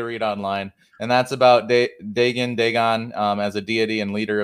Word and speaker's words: Dagon: It depends. Most people would pronounce it Dagon, but Dagon or Dagon was actually an ancient Sumerian entity that has connections to the Demiurge. So historically, you'd probably say it Dagon, but the way he pronounced it and other people do, it's called Dagon, I believe.